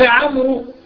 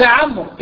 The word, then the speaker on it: عمرو: صح أن نقول عمرو ابن زياد، الواو دي الواو جاءت بس للتفرقة بين عمر وعمر، لكن ما ينفعش أقول عمرو، هو عمرو ينطق عمرو، لكن الواو لا تنطق، الواو جاءت فقط للتفرقة بين عمر وعمرو، تمام؟